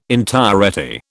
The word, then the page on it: entirety: British English